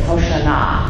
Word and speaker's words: Hoshana: And I love the Hebrew pronunciation of Hosanna. It's Hoshana, Hoshana.